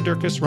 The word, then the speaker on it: Durkis: Sarah Durkis, Rhymes with Circus,